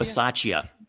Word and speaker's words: Bisaccia: Um, I don't want to butcher his last name, Rich B- Bis- Bisaccia. – Bisaccia. Bisaccia. That's right.